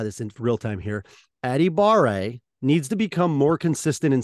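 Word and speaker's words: Adebawore: So if you're looking for a semi, it's Tommy Adibare. That is how you pronounce it. Is Aditamiwa Adibare. That is how you say it.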